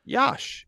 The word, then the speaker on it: Yash: First name rhymes, rhymes with Josh. Josh. That's right. So it is Yash. – Okay. – Yash.